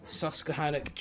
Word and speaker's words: Susquehannock: So for anyone that didn't know, STPR, Susquehannock Trail Performance Rally. Guys, have I pronounced that right or is that wrong? – Yeah, right. Sounds on, right, man. Well done.